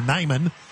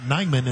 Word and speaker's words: Neiman: Hitting air by Neiman, or Nyman. It looks like Neiman, but they pronounce it Nyman in Aberdeen.